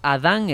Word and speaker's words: Adán: Adán Hernández, con acento en en la última A.